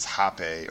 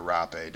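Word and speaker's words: Rāpe: And the one that we actually had at the studio that David did a couple ceremonies, Hape or Rape, depending on how you pronounce it. It's spelled R-A-P-E with a line over the E. So it's kind of easier to say Hape because it doesn't sound as bad as it looks when you write it.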